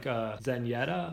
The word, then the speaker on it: Zenyatta: Number 3 is probably like Zenyatta. That's how you say it, right? – Yeah.